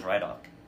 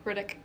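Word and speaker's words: Ridoc: Is it pronounced that way? I always thought it was Rydock. – Nope, Riddick.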